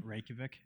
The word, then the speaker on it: Reykjavik: How the hell do you pronounce the capital of Iceland? – Reykjavik. – Is that how you say it? Okay.